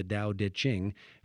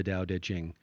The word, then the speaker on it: Tao Te Ching: It's clearly the letter T in English, but it seems to be pronounced more like a D.